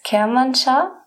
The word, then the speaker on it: Kermanshah: Du bist ja in einem Ort namens Kermanshah aufgewachsen richtig.